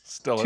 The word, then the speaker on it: Stillers: Also, I read today that to pronounce Stillers correctly, you've got to keep your tongue on the bottom at the back of your mouth. That's how to properly say Stillers. Stillers.